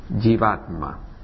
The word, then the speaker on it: Jivatma: Jivatma. Se pronuncia así, la J se pronuncia como una Y al principio.